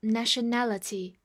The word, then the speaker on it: nationality: 第一种是在它后面直接加 al 这个形容词后缀，意思就是国家的、国民的 national，然后再在这个形容词基础上后面加 ity 是一个名词后缀，就变成国籍的意思，读作 nationality。注意一下重音的位置，所以当别人问你 "What's your nationality？"